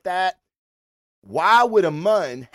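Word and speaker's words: Muun: Darth Plagueis is, he was a Mun, which was a very tall, I pronounced that right, Jason, Mun, M-U-N-N, I can do that shit.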